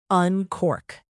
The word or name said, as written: uncork